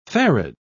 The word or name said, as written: farad